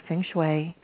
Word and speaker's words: feng shui: She also integrates the power of feng shui. Did I say that correctly, Tracy? – You sure did.